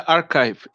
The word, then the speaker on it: Archive: Archive, Archive, Archive, ¿cómo se pronuncia? Archive. – Se pronuncia Archive, Internet ah.